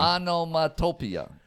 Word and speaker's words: onomatopoeia: O-M-A-T. – Tomato. – Onomatopoeia. – No. Start at the beginning. – Onomatopoeia. – You getting. – Onomatopoeia. – Okay. – No he just. – So what is that letter? – Oh, it's O-E, so it, but it's pronounced like just E.